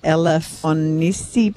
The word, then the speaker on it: Elafonisi: Elafonisi Beach.